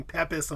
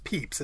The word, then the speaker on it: Pepys: It was one of those really proud moments for me because I've heard people going Pepys, and I go, actually, it's pronounced Pepys as a really snobby little person.